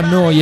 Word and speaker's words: Neu: Si scrive Neu, si pronuncia Noi e vuol dire nuova, nuova emittente urbana.